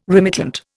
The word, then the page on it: remittent: Indian